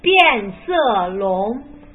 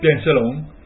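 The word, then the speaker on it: Pianselong (变色龙): Pianselong. – Vabbè, questa è la pronuncia proprio esatta, ma quando si parla si dice Pianselong, che Pianselong.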